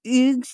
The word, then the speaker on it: Hugues: I will say that I did some research, and the correct way to pronounce Hugues' name is Hugues.